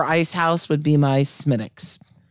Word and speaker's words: Smithwick's: And pronounced Smittix for those at home.